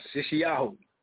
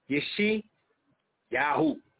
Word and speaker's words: Yeshayahu: Thank you. – Yes, Yeshi Yahu. Yeshi Yahu.